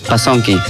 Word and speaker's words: Basonki: No sé si es Ashaninka, pero sí, Basonko, que es gracias. – Basonki. – Ah, Basonki.